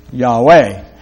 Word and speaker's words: Yahweh: Some say Jehovah is properly pronounced Yahweh.